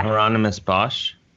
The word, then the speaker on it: Hieronymus Bosch: It was kind of like, and this is a negative connotation, but I don't mean it this way, but you know, is it Bosch or Borscht, the German painter that does the Hieronymus Bosch. – Yeah. Hieronymus Bosch. – Yes, exactly. Thank you. – I think I'm saying that right. I don't know. – Yes. I think you're saying it right too.